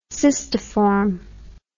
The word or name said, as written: cystiform